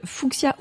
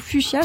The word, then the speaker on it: fuchsia: Petit rose fuchsia ou fuchsia, selon comment on le prononce.